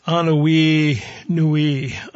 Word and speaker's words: Anuenue: It's from Hawaii and it's called Anonui Nui. I'll spell that for you. A-N-U-E-N-U-E.